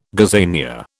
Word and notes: gazania: British English

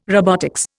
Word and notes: robotics: Indian

